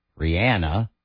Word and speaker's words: Rihanna: Rihanna, that's how she pronounces it.